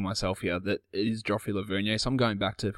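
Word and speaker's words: Joffrey Lauvergne: And okay, now I've been trying to work out, I've always just pronounced his name as Joffrey Lavernier. Never heard anyone else say it that way, so I've transitioned into Laverne.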